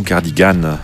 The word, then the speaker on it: cardigan: Le mot anglais qui désigne une veste de laine tricotée à manches longues et boutonnées devant, c'est le cardigan ou cardigan, si vous prononcez à l'anglaise.